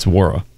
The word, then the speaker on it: Nwora: Are we correct on this new Pacer, the silent N, Jordan Wara? – I asked my brother because he's a Bucks fan. He said it's Wara. – Yeah. It's like Chuka Okoro. The N is silent.